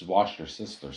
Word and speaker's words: Worcestershire: Worcestershire. – It's wash your sister sauce. What? – Wash your sister sauce? – That's how you say it.